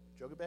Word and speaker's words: Jochebed: With, how do you pronounce that? I'm going to pronounce it wrong, I know. But no, Jochebed? J-O-C-H-E-B-E-D? Jochebed? Yeah, okay. Jobed? Okay, thank you.